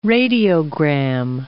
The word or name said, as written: radiogram